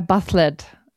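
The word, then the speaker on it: bat'leth: Und es gibt einmal die Normal-Original-Aussprache im Klingonischen. Die in der englischen Variante, bitte? – Ich will sofort hören, wie es auf Klingonisch heißt. – Ja, Bathlet. Okay. Aber, also das ist halt so ein Kampfding. Und im Englischen haben sie es auch so ähnlich ausgesprochen, Bathlet, glaube ich. – Okay. – So. Aber im Deutschen, in der Synchro, hieß das immer Bathlet.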